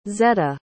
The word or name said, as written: zetta